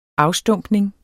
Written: afstumpning

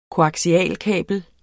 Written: koaksialkabel

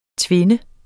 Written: tvinde

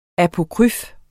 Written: apokryf

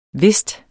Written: whist

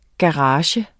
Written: GARAGEN

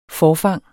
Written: forfang

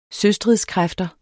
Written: søstridskræfter

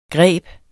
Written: greb

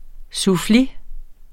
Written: suffli

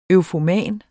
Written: eufoman

